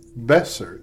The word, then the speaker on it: Besser: Jag har fått lära mig, jag har haft så svårt att uttala Besser i Vancouver. – Just det. – Men tänk på mig och tänk på Besserwisser.